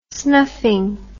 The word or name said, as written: snuffing